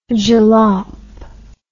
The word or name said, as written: jalop